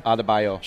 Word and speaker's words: Adebayo: Adebayo.